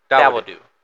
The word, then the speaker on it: Dawodu: How do you say his last name? – Dawodu. – I think it's Dawodu.